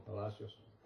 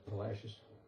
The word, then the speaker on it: Palacios: Those blue letters indicate that, or suggest, that somebody a long time ago named that town Palacios. But modern-day Texans pronounce it Palacios. which kind of sort of rhymes with fascist if you think about it.